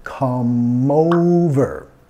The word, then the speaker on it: come over: La E al final de come es muda. Y vamos a vincular de la M al O. Come over.